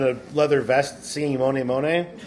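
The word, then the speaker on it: Mony Mony: How's it pronounced? – Moni Moni. – Like M-O-N-Y.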